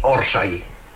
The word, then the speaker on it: orsay: Orsay es una palabra llana que ha de llevar acento en la primera sílaba, en la O.